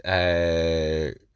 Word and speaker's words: Qatar: My younger brother, friend of the show and future guest, Aidan McKeown, he's based out in Qatar, as he endlessly tells me it's pronounced.